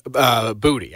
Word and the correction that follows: Boutte: I do. I think that's Boutte. – Boutte. – I think so. – Okay, Boutte. Kayshawn Boutte.